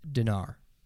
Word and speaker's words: dinar: Dinner? – Dinar. D-I-N-A-R. Dinar is how you pronounce it.